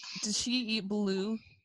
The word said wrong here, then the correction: balut: Oh, balut, you mean? – Because that's a Filipino thing, right? – It's pronounced balut.